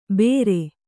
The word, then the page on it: ಬೇರೆ: bēre; Kannada